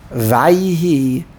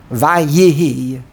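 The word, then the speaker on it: Vayihi (וַיְהִי): The proper way to do it, according to rules of grammar, it's Vay. Vayihi, a Shvanoch. The Yud should be Vay. Vayihi Bimei Achashverosh.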